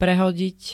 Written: prehodiť

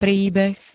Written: príbeh

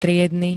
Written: triedny

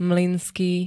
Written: MLYNSKÝ

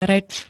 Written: rec

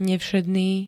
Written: nevšedný

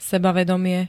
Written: sebavedomie

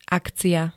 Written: akcia

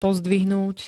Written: pozdvihnúť

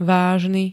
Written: vážny

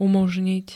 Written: umoznit

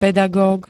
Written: pedagóg

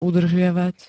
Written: udržiavať

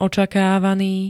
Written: očakávaný

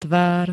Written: tvár

